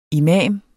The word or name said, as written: imam